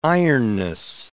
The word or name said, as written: ironness